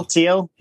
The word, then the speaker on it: Thiel: Thiel Peter Thiel yeah.